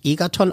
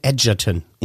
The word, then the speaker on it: Egerton: Der wird nicht Egerton ausgesprochen, sondern Egerton.